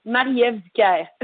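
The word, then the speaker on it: Marie-Ève Dicaire: Well, I'm going to pronounce it the French way, and you can adjust it in English. It's Marie-Ève Dicard.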